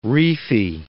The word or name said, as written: reefy